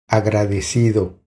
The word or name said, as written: Agradecido